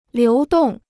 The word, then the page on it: 流动: liú dòng